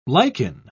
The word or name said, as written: lichen